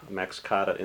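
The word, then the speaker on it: Max Kade Institute: You talked a little bit about the Max Cotta Institute. Am I pronouncing that right? – Mm-hmm.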